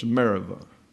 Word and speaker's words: Meribah: So that means it's not a B as you have in the transliteration, but a V in the Hebrew. So it's Meribah.